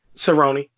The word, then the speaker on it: Cerrone: Cerrone. Yeah, Cerrone.